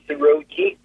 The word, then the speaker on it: Rogi: It's Rogi, R-O-G-I.